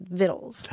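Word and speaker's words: victuals: Yeah, it's spelled like vitals, but it's pronounced vittles.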